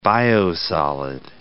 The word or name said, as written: biosolid